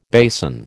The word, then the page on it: basin: American English